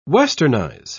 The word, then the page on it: westernise: British English